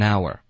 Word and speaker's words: hour: Exactly, 比如一个小时是 H 开头的可是它的发音是 R, 这是一个元音所以它肯定会连读到前面的音。 Right? – 啊 ?An hour.